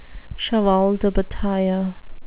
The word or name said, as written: cheval de bataille